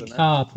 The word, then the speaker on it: Descartes: Descartes ne? wird es ausgesprochen.